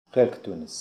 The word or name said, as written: Helkdonis